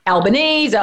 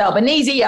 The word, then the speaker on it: Albanese: And the legacy of that is that he didn't really know how it was meant to be pronounced as he grew up. So he, he, he answers to a whole lot of pronunciations Albanese, Albanese, Albanese.